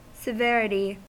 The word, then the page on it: severity: American English